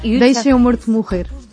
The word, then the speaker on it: Deixem o Morto Morrer: It's in Portuguese, it's very hard to pronounce Portuguese, but it's almost. – No, you deixem o morto morrer. We eat the vowels, so it's very hard.